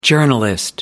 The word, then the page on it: journalist: American English